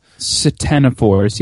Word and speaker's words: ctenophores: So they found that through sequencing that ctenophores, or I don't know why they spell any words, C-T-E-N-C-T. How do you pronounce that? Ctenophores? – Ctenophores, yes.